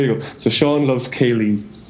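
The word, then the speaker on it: céilí: So if you look up Kaylee music, you'll get a ton of very fast. High tempo traditional Irish music, and phonetically it's pronounced some other way, but it's exactly the same as Kaylee.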